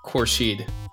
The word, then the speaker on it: Khourshid: How exactly do you pronounce your last name, David? – So my last name is pronounced Khourshid, or at least Khourshid. I think it is.